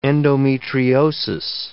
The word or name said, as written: endometriosis